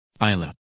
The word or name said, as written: Isla